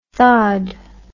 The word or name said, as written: THAAD